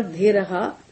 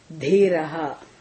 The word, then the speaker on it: Dhiraha: In Sanskritam, remove that in, okay? Put Visarga. Visarga na, it has to be pronounced as ha, okay? Dhiraha. Dhiran.